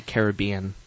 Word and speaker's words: Caribbean: I think the correct way to say it would be Caribbean.